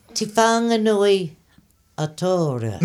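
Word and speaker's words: Te Whanganui-a-Tara: I was guessing what the, Te Whanganui Atara. – No, you said it right, and we'll just lock it in there, and we'll we filmed that.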